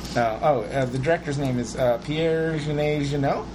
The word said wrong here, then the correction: Jean-Pierre Jeunet: Hold on. I mispronounced that. – Pierre Genet Jeannot. – Did I say that wrong? Jean-Pierre Jeunet.